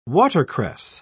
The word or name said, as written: watercress